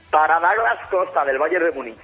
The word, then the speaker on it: Douglas Costa: Suena un poco extraño, pero es que en Brasil se dice así, Douglas Costa, tal y como se escribe, se pronuncia.